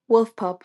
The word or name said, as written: wolf pup